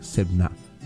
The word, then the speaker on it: Sebna: Se escribe S-E-B de burro N-A, Sebna.